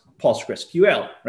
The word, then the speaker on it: PostgreSQL: First of all, let's clarify how to pronounce Postgres. Yes. Okay. So the official name is PostgreSQL, which is pronounced PostgreSQL, right? So post, like a blog post, that, that should be easy. By the way, I, am I'm, I'm talking about pronunciation and I'm not, not, not a native English speaker, so I might get it totally wrong, but bear with me. So post, like post, that's easy. Gress, it goes after that.